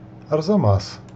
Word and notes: Арзама́с: ərzɐˈmas; Russian

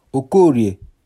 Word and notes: Okorie: Igbo

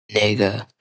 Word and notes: Nigga: ˈnɪɡə

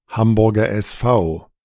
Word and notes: Hamburger SV: [ˈhambʊʁɡɐ ʔɛsˈfaʊ]; German

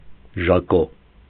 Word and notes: Ժակո: ʒɑˈkɔ; Armenian

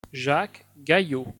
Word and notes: Jacques Gaillot: French

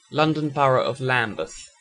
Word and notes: Lambeth: ˈlæmbəθ; British English

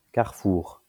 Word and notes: Carrefour: kaʁfuʁ; French

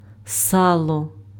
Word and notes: сало: salo; [ˈsɑlo]; Ukrainian

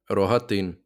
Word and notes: Рогатин: [roɦɐˈtɪn]; Ukrainian

